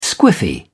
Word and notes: squiffy: British English

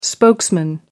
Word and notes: spokesman: American English

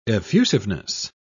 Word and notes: effusiveness: British English

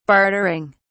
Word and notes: bartering: British English

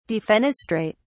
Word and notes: defenestrate: British English

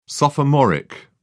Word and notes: sophomoric: British English

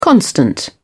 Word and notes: constant: British English

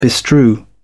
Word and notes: bestrew: British English

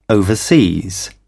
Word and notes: overseas: British English

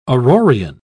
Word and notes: aurorean: British English

